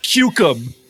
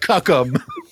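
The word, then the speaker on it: Cuck'em: It's pronounced Cucum, by the way. It's spelled C-U-C-K apostrophe E-M. It's spelled like Cuckum, but it's pronounced Cucum.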